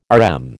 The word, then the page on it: RM: American English